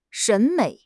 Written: 审美

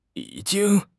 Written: yi3 jing1

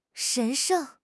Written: 神圣

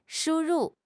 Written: shu1 ru4

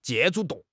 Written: 借助

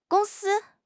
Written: gong1 si1

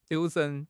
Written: 留神

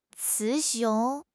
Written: 雌雄

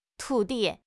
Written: 徒弟

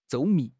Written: 周密

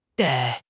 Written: dai4

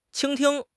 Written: 倾听

